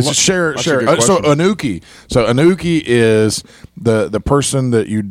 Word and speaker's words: uke: Don't zone out, Ookies.